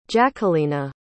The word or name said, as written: jaquelina